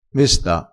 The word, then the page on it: mesta: dialettale